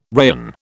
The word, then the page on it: rayon: British English